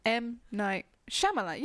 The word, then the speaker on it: Shyamalan: Is this another name we're going to mispronounce? M. Night Shyamalan. Yeah, you got it right. I do think that's how you say it.